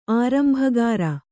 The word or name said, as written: ಆರಂಭಕಾಱ